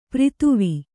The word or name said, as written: ಪ್ರಿಥುವಿ